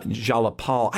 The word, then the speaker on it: Jayapal: And it really centered originally and earlier in the week when Congressional Progressive Caucus Representative Pramila Jayapal. I always get her name wrong. Say that. – Jayapal. I'm not exactly sure about her first name either, but Jayapal is correct for her last name.